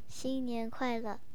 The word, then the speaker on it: 新年快乐: 新年快乐. – 新年快乐? – Yeah. – 新年快乐. Oh, man, I'm so far from it. 新年快乐. 新年快乐.